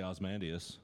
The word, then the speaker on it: Ozymandias: Or is that how you say it? Ozymandias? Say it for me. – I just say Ozymandias. – Okay. – But you can put the E on there. I don't think anybody's going to give a shit. – Yeah, it's got the Y in there. You can pronounce it either way.